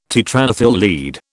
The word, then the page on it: tetraethyl lead: British English